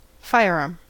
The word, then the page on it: firearm: American English